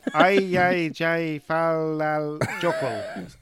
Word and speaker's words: Eyjafjallajökull: Famously known as the Eyjafjallajökull.